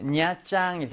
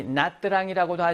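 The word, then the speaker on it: Nha Trang: 줄릭, 냐짱, 이렇게 돼 있어. 우리 나뜨랑이라고도 하지? 이거를 이제 다른 지역, 남부 지역에서는 나뜨랑이라고도 해. 우리는 지금, 하노이, 수도 하노이어를 표준어로 보고, 하노이어를 기준으로 해서 하니까, 냐짱이라고 읽는데, 자, 냐짱 여행이야.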